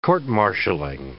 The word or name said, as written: court-martialling